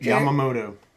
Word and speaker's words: Yamamoto: Yamamoto.